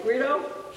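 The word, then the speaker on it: güiro: and they're called guiros. And I've been wanting to try to learn, am I saying that right? Guiro? Guiro.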